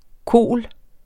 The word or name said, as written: KOL